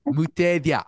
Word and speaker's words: Mutethya: Did I say that right? – Mutethya. – I'm sorry. Mutethya. I had it. And then I said it wrong.